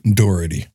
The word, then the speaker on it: Dougherty: How do I say his last name? Dougherty. Doherty. Doherty. There we go.